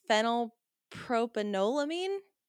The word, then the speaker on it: Phenylpropanolamine: Let's see if I can pronounce it. Phenylpropanolamine? – That sounds right.